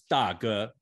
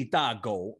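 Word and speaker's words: da ge (大哥): he would call me, you're my, and Eric, you'll know this, in Chinese, big brother is da ge, okay? So instead of da ge, he would say, hey, da go, which is big dog instead of big brother, right?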